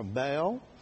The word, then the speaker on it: Baal: or Baal. Some of them pronounce it Baal, I found out, but it's, I think the true is Baal.